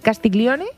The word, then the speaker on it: Castiglione: La condesa de Castiglione. – Castiglione.